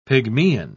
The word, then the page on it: pygmean: British English